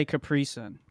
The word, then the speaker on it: Capri Sun: So the emphasis is on the sun. – Well, the emphasis is on the last syllable. Yeah, it's I put it on the pre, Capri Sun.